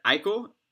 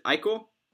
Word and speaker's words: Eichel: Is it Eichel? – You said it correctly. I'm kind of impressed. – All right. Yes. – Yeah. I always tell people it's just like Michael. Just, just get rid of the M and you just got Eichel.